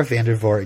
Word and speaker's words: Vandervoort: Laura Vander Hooten? What's her name? Laura Vander. – Look, it's Laura Vandervoort, and you know it's her name, and you're just mispronouncing it on purpose.